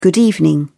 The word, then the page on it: good evening: British English